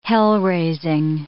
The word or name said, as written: hell-raising